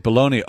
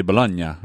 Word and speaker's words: Bologna: He's like, bologna. Oh, it must be how it's pronounced in Italy. No. They say Bologna or whatever. – Yeah. – It's definitely not bologna.